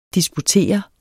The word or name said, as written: disputere